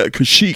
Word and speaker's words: Kashyyyk: Yeah, Kashyyyk. – Kashyyyk, yeah. So I well, a freak. – It's like Kashyyyk rhymes with a freak.